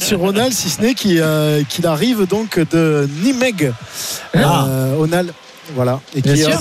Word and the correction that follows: Nijmegen: Ah, comment on dit ? – Nijmegen. – Nijmegen. Nijmegen. Voilà, il vient de Nijmegen. C'est facile de le dire.